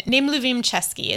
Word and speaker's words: nemluvím česky: And my go-to phrase when I was in the Czech Republic, people would try to talk to me in Czech and nemluvím česky is how you say I don't speak Czech